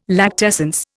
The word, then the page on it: lactescence: Indian